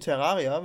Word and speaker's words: Terraria: Es ist so, man kann sich das vorstellen wie so ein bisschen Terraria, wenn man das möchte.